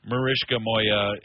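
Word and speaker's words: Maryszka Moja: The original title is believed to be Mariska Moya, and I would spell it written in Polish, M-A-R-Y-S-Z-K-A.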